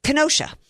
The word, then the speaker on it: Kenosha: So in Wakosha, how do you say it? Kenosha, Wisconsin. I can never get the name of that right.